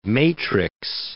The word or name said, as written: matrix